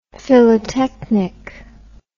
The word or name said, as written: philotechnic